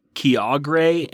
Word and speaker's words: Kyogre: It's actually pronounced Kyogre and Gruden.